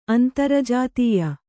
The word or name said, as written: ಅಂತರಜಾತೀಯ